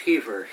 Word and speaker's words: Kiefer: George Kiefer, K-I-E-F-E-R.